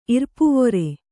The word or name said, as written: ಇರ್ಪುವೊರೆ